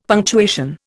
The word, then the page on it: punctuation: Indian